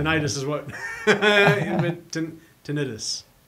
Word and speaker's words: tinnitus: Tinnitus? You know that's the correct way to say it? – Tinnitus? – Yeah.